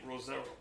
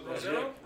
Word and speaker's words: Rosero: So, who is Devonte Rosero? Did I say your last name right, first of all? – Yeah, you did. – Rosero? – Rosero, man.